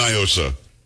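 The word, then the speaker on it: NIOSA: Do not, because it's definitely Nyosa. It is not a Spanish word. What does Nyosa mean in Spanish? Nothing. – I don't know. – Well, that's the whole argument. They try to pronounce it like it's Spanish. It's not.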